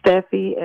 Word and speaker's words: Stefie: It's at Steffi, S-T-E-F-I-E, Sales, S-A-I-L-S.